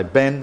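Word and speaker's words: Bain (בן): which is pronounced Bain, or some anglicizers might say Ben, as in Benjamin, meaning son of Jarmon.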